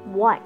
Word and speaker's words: white: 我可不可以储存我的行李在这边？Store, one store, two stores.一个店两个店是 store 又有商店也有储存的意思英文很奇怪哈 And let's have a look of white W-H-I-T-E white 我们都知道这个是白色的意思但是也有人的姓是 white 然后我们看看这个字 E 是最后一个字 E 在这边是不发音 E 的前面是 T T 是一个子音子音的前面这个时候英文 99.9% 的规律是在这个子音前面的那个元音发的是长音所以 I 在这边是 I white I love white as a color I do like white white I white shout S-H-O-U-T shoutWhat are you doing with my car?